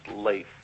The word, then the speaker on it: Leif: It's Leif, Mr. Chairman. – Like the rock star? – Yes.